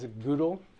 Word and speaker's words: GDAL: We're going to look at a tool called GDAL, or as you might hear it pronounced sometimes, GDAL, Geospatial Data Abstraction Library.